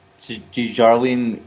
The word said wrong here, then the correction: Darjeeling: I don't know if I'm pronouncing that right. – Dejarlene Limited. I'm pretty sure that the girl that's in that movie, I have to... How do you... Okay, so we would look for... How about Darjeeling Unlimited? – How do you say it? – Darjeeling Unlimited? – Darjeeling.